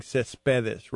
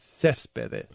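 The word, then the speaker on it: Céspedes: We've been pronouncing Cespedes wrong. It's Cespedes.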